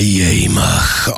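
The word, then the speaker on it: Jemig: It is my Yamach of the week. If you're a new listener, you're always wondering, what the hell is a Yamach? Or Jamig, as it's spelt in wonderful Dutch language.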